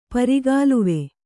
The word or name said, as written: ಪರಿಗಾಲುವೆ